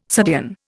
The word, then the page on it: subdean: Indian